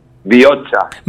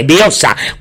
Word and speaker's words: Bihotza: Bioza. – Vale, Bioza.